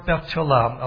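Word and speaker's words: betulah: But another key word to deal with is the word Betulam. I'll spell it for you. B-E-T-U-L-A-H. B-E-T-U-L-A-H. Betulam.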